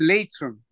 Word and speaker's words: Leitrim: And now listen, you're from, am I pronouncing it right by saying Leitrim in Ireland? – You're close. Leitrim is what it's Leitrim.